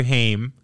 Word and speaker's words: Haim: Their second movie together, Corey Haim, got his name right. – You did. You didn't call him Ham.